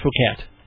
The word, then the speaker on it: Phuket: Well, that is actually pronounced Phuket. – It's Phuket.